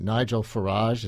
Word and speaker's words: Nigel Farage: So you also have ranters like this, British representative to the European Parliament, Nigel Farage, is that how you say his name? – Yeah.